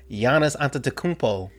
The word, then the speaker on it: Giannis Antetokounmpo: I can say Giannis Antetokounmpo.